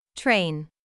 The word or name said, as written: train